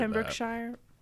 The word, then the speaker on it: Pembrokeshire: Sorry if I butchered Pembrokeshire.